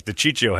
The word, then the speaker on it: DiCiccio: But I was like, DiCiccio had me right off the bat. He doesn't say his name right. It's DiCiccio. Sal DiCiccio. No. Don't say sissy.